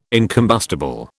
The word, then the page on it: incombustible: British English